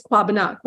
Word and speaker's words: Kwabena: And because that was the first grandchild, he said, well, can we name him after my dad, which is Kwabena, K-W-A-B-E-N-A. When people read it, it looks like Kwabena, but it's Kwabena, or Kwabena, depends on how you want to put the accent. But traditionally, it's Kwabena. Kwabena is the exact pronunciation.